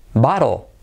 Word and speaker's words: bottle: Número 93, botella, bottle, bottle, no me des la vara, bottle. Bottle, fíjate la L, o, casi L en posición final, casi no se pronuncia.